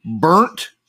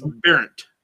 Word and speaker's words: Bernt: Well, it's B-E-R-N-T is his first name. – Yeah, I think it's Bernt. Bernt. – And then K-A-I is the second name, which I'm positive is Kai.